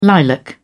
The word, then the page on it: lilac: British English